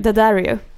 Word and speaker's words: Daddario: Det är alltså Matthew Daddario, eller hur man nu uttalar hans Daddario.